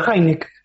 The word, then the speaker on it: Hynek: Hynek.